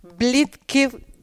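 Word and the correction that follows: Blitzkrieg: lo he dicho mal. Bleed Krieg.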